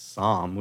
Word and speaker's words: SAHM: And it's SOM, which is kind of phonetically how you think it would be pronounced.